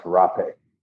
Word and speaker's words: rapé: It is spelled RAPE, like rape, but with the little line above the E. I forget. Oh, okay. – Or some people will spell it RAPEH, like phonetically, but that's not how it's actually spelled, but that's how it's pronounced, rapé.